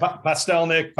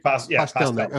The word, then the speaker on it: Pastelnik: So instead of just being like, oh, we're talking, Daryl, how do you say your last name? Postelnik? – Sure. Good enough. Yeah. – Well, no, you tell me how you say it. – Pastelnik. Pastelnik.